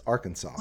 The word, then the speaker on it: Arkansas: It's codified as Arkansas.